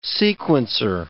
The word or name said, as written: sequencer